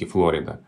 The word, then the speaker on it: Флорида: Ну вот, пожалуй, пример Флорида общеизвестен, да, что по-английски Флорида.